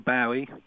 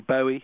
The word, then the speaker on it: Bowie: At the start of the 1970s, when David Bowie, and I say Bowie, you say Bowie. – Bowie in Britain. That's good to know. – That's right. It's an interesting distinction that the name seems to be pronounced in different ways.